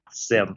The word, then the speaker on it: SIEM: I think it's even pronounced SIM, but I sometimes say it wrong myself. SIM.